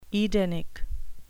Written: edenic

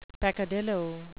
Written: peccadillo

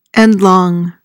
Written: endlong

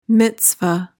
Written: mitzvah

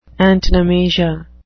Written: antonomasia

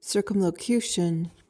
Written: circumlocution

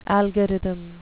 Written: algorithm

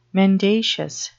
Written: mendacious

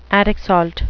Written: attic salt

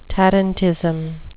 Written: tarantism

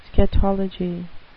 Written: scatology

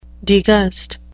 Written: degust

